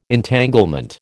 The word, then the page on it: Entanglement: American English